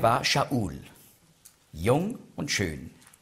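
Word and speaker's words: Sha'ul (שאול): Und werden sehen, der Name Saul, auf Hebräisch spricht man aus Sha'ul.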